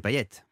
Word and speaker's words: Payet: Non mais c'est vrai parce que à la Réunion le thé de Payet se prononce, ouais. Figurez-vous. Et c'est ce qui fait déjà la première particularité du bonhomme que je suis, c'est-à-dire que ce n'est pas un thé muet.